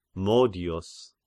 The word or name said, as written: Modios